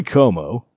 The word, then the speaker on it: Como: Corey Como. – Rhymes with Romo.